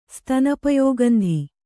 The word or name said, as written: ಸ್ತನಪಯೋಗಂಧಿ